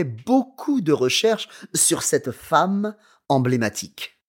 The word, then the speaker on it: femme: Car il a fait beaucoup de recherches, because he's done lots of research, sur cette femme emblématique, on this symbolic woman. – Yeah, and watch the pronunciation of femme. Okay, it's quite a tricky word. – Femme, F-E-M-M-E, femme.